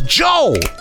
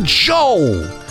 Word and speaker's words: Joe: It's Joe. You know what I'm talking about? Joe. It rhymes with go, and that's why I gotta go.